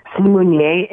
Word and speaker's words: Simunye: I couldn't pronounce the word Simunye. Is that how you say it? – Simunye. – And what does it stand for again? – Simunye in Zulu means we are one.